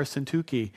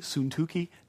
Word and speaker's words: Syntyche: That's how you pronounce it. It doesn't look like it. I feel so silly saying it correctly. Syntyche. Doesn't look like it should say Syntyche, but that's what it is.